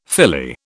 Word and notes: filly: British English

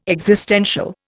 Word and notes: existential: Indian

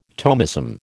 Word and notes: Thomism: American English

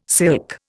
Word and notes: silk: Indian

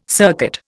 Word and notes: circuit: Indian

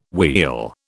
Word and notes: wheal: British English

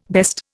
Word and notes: best: Indian

